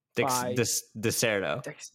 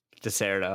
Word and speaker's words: Dexerto: Our next article brought to you by Dextro. – Dexerto. – Is it? – Dexerto. I say Dexerto.